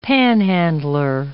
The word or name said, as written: panhandler